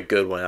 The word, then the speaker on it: Goodwin: So hopefully he can do that opposite Pierre Garçon and Godwin. And they also have Kittle up the middle. So, you know, that you compare those weapons, Godwin, Kittle, it's probably Goodwin, I'm pronouncing it Godwin.